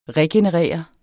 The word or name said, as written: regenerere